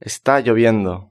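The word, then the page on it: esta lloviendo: Spain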